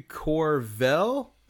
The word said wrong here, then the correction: Colville: Is it Colville? – Colville. Yeah. Thanks.